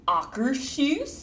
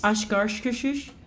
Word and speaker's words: Akershus: We ate at Akershus. – Ashgarshkashus. – Which is the proper pronunciation, Akershus. Not necessarily with that tone, but.